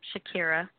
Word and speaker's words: Shekeira: S-H-E-K-E-I-R-A. Shakira.